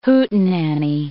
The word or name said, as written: hootenanny